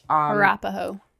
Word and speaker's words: Arapaho: Arapaho. – Arapaho, thank you. I was like, I know I'm like overpronouncing.